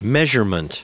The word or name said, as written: measurement